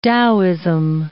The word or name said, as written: Taoism